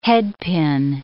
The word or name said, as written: headpin